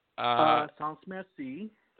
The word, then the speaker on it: Sans Merci: I'm not sure if I'm pronouncing this right, but is it Saint Merci album? – Saint Merci. – Saint Merci. Sorry.